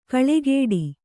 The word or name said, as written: ಕಳೆಗೊಡು